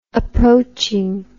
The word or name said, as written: approaching